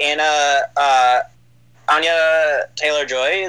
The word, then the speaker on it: Anya Taylor-Joy: Anya Taylor-Joy. Is that how you pronounce her? – Yes. Yeah.